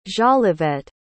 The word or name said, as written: jolivette